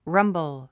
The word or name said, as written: rumble